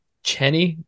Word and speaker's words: Cheney: Today we are here with Habtamu Cheney.